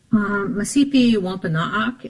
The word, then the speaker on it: Mashpee Wampanoag: and we typically refer to it as Mashpee Wampanoag, but how do you say the name of your tribe and your language? – Masipi Wampanoag is the way you would say Mashpee Wampanoag traditionally.